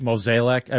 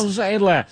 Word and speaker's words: Mozeliak: Mozeliak, how do you say it? – Mozeliak, I do believe. – Yeah. Whatever it is. Yeah, it doesn't make a difference. – Mozeliak. Just say it like Harry, and you're always good.